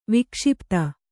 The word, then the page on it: ವಿಕ್ಷಿಪ್ತ: vikṣipta